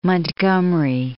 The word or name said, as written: Montgomery